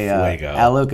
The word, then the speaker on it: Alok: So, I fell in love with this song by I believe you say it like Alok and Bashar. A-L-O-K and B-A-S-H-A-A-R.